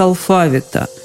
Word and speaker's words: алфавит: Вообще-то преподавателю языка, даже иностранного, следовало бы знать, как произносится слово алфавит в русском языке. Только так, алфавит – в орфоэпическом словаре под редакцией Аванесова рядом с вариантом «алфавит» стоит строгая помета «не рекомендуется».